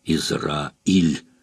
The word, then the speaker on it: Израиль: Она делала ударение на конце слова. «Израиль».